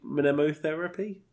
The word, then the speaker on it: mnemotherapy: Can anyone say mnemotherapy? Is that how you say it? – You just did.